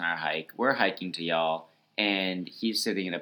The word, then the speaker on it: Youghal: In Y'all, which is not pronounced Yugle. – It's spelled Y-O-U-G-H-A-L for those who aren't aware. – That's right. But it's definitely pronounced Y'all, as we heard.